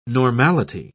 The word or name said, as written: normality